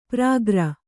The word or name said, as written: ಪ್ರಾಗ್ರ